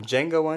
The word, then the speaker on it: Django: Touching on the topic of literacy again, here's a clip from Django Unchained. Run it! – What's your name? – Django. – Can you spell it? – D-J-A-N-G-O. The D is silent.